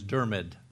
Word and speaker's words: Dermid: This man, his first name, because I learned it through an Englishman who's a scholar, his name is Dermid. That's the way you pronounce his name, McCullough.